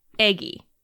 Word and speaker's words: Aegy: Today, in this moment, I am playing Eggy. – Eggy? – Eggy. – Go on. – It's spelled A-E-G-Y. And good. In your heart, you pronounce it like the word egg with 2 G's and 1 E.